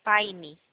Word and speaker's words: Pine: Facta Prime. – Pine. Se escreve Pine.